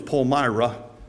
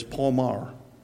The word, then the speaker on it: Palmyra: You got to pronounce it differently in Pennsylvania. In New York, it's Palmyra. Down here in Lancaster County, it's Palmar.